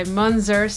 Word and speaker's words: Munzur: This is the tail end of Back Again by Munzur, spelled M-U-N-Z-U-R, the extended mix, kind of reminds me of Munster cheese when I hear the name pronounced, and I actually really like Munster cheese, so Munster, that's a compliment to you.